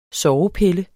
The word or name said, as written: sovepille